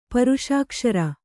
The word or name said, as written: ಪರುಷಾಕ್ಷರ